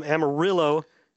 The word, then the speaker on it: Amarillo: Finally, from Steven Larson from Amarillo, Texas, uh-huh. – and I'm pronouncing it correctly because he made a point to tell me pronounce Amarillo, not Amarillo.